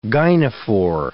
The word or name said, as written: gynophore